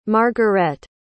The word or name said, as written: margarette